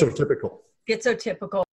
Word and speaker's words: schizotypical: Schizotypical.